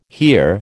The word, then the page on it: hear: American English